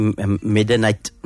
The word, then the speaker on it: Medinan: It is a Medinan. Did I pronounce it properly? Medinan? – Medinan, or you say Medinite. – Oh, Medinite, okay.